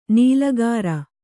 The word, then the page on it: ನಾಲಿಕೇರ: nālikēra